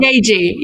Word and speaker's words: Yaeji: Y- uh, Yaeyi? You know, make it rain, girl, make it rain, make it girl. You know that song? Yaeji, Yaeji, Yaeji.